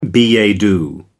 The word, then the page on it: billet doux: American English